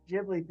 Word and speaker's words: Ghibli: Anyway, back on the Ghibli thing, like, if someone said that to me, I would just be like, well, there's an H. Ghibli is how it's spelled.